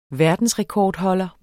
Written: verdensrekordholder

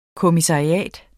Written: kommissariat

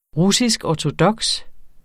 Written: russiskortodoks

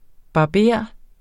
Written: barber

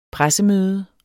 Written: pressemøde

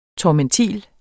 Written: tormentil

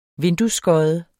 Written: vinduesskodde

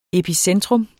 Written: epicentrum

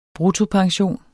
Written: bruttopension

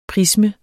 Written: prisme

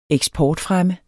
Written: eksportfremme

